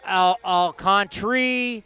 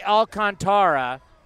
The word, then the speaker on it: Alcantara: So I've heard Alcantri, Alcantara. We're going to get the official pronunciation of Sandy Alcantara, because that's what I believe it is. That's what Baseball Pronunciation Guide says.